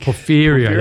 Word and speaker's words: Porfirio: how are we pronouncing that horse? – Porfirio, I think. – Porfirio, that's right. Porfirio. – Yeah, just Porfirio, so.